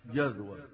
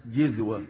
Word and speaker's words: جذوة: هي تنطق في لغة العرب بالفتح جذوة، وبالكسر جذوة، وبالضم جزوة.